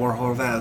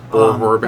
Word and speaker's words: Horvat: I want to kind of tie mine in as well, because I also had Bo Horvat as well. – Horvat. – I said that wrong.